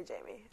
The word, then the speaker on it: Jamie: Jame. Is that how you say it? – Well, that's what Alana calls him. – He's the drug dealer. – She also calls her vagina a vanyanya. – A vanyanya. So, I like... How do you say it? – Just say Jamie.